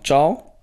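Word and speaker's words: Thiaw: Malik, wird er Malik Ciao. – ausgesprochen? Ciao. OG kam für Solemarkers oder Selemarkers.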